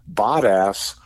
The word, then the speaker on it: Bottas: Valtteri Bottas has raised $150,000 for men's health charity Movember after a, now this is the other pronunciation, Bottas 2024 calendar featuring photographs of him bearing his backside sped off the shelves.